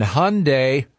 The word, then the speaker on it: Hyundai: Right now, I want to talk to you about things that rhyme, like Sunday and Hyundai.